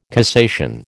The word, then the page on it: cassation: American English